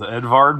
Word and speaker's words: Edvard: His name is Edvard Munch. – Edward. Well, it says E-D-W-A-R-D. And in America, that's Edward. So it's Edward Munch. – Shannon, calm down. Calm down. Remember how he pronounces the restaurant that's spelled S-T-O-N-I-E.